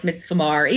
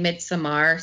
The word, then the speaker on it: Midsommar: And, you know, Ari Aster is just, like, my favorite, you know, thing in horror right now because of Midsommar, which I guess I've been mispronouncing and I feel, like, really silly about, because, like, I was watching Eli Roth's History of Horror and they're all, like, Midsommar, even Ari Aster. And I was like, okay, I wasn't saying Midsommar, so now I feel dumb.